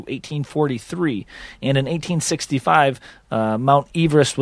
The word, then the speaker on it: Everest: Now, Sir George Everest's surname is pronounced Everest, with Eve pronounced as the woman's name, but everybody refers to the mountain as Everest.